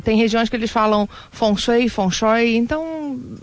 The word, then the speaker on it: Feng Shui: É, bom, pode falar Feng Shui, tá? Não tem problema. A pronúncia é mais parecida na China é algo como Feng Shui feng shui.